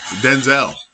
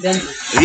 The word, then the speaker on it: Denzel: So his name is Denzel. – No, Denzel. – Denzel. – It's Denzel, and his mama called him Denzel.